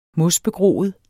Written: mosbegroet

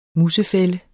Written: musefælde

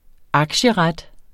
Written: aktieret

